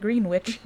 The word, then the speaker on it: Greenwich: I didn't know it was Greenwich until studying music from New York. – Oh. – And then the teacher was like, yeah, it's Greenwich. And I was like, what the fuck? I've been saying this wrong, like, for 20 years.